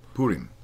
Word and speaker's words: Purim: Purim.